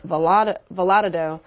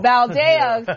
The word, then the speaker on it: Valadao: currently represented by Republican David Valadao, I think I'm pronouncing that wrong. – Valadao, thank you, and being challenged by Democrat Randy Villegas.